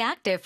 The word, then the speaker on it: active: 근데 active 뒤에 부까지 발음하지 않습니다. – Active. – Active. – 아주 짧게 the. – 어. – Active. – Active. – Active. – 그 부라고 하고 싶겠지만 거기서 딱 끊어서 active.